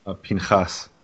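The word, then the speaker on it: Pinchas (פינחס): And down in verse 25, we have Phineas. Have I pronounced that correctly? – Pinchas.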